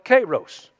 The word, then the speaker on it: kairos: Now, there's another part, another word that represents a form of time called kairos. And if you want to spell that, it's kind of like kairos syrup, only it's K-A-I-R-O-S.